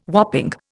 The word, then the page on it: whopping: Indian